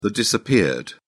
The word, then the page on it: disappeared: British English